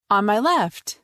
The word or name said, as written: On my left